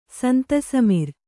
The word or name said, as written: ಸಂತಸಮಿರ್